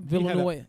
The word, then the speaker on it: Villanueva: Villanueva.